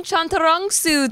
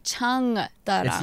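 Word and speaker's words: Chantarangsu: We got Tim Chantarongsu today. – Oh, that was good. – Thank you. – Yeah. Is that how you say it? – Chantarongsu. – I always want to say chung darang.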